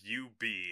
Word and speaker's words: Ub: Yeah, his name is Ub Ub Iwerks. – How else would you say it? – Ub. Ub Ub.